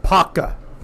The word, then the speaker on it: Apopka: It's pronounced Paka. – Paka. – Okay. – Paka. Those of you who live in this area, you know it's Paka.